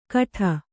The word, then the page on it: ಕಠ: kaṭha; Kannada